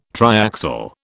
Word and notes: triaxial: British English

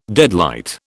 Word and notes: deadlight: British English

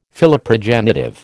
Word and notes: Philoprogenitive: American English

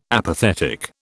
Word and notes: apathetic: British English

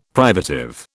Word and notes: privative: British English

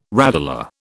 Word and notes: radula: British English

